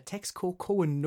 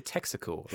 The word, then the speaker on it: Texcoco: Are you trying to say Texaco, like the oil company? No. – The city, Texcoco. A person who's from Texcoco is called a Texcocoan. – Okay. – So a Texcocoan nobleman, he described how these flower wars started.